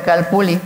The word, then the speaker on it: Calpulli: pero es Calpulli, se pronuncia Calpuli, viene del náhuatl.